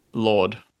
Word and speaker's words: Lorde: Is it literally Lord? – L-O-R-D-E.